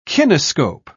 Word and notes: kinescope: British English

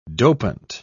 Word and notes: dopant: British English

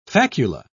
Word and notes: facula: British English

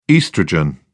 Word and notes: oestrogen: British English

